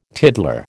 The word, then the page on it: tiddler: American English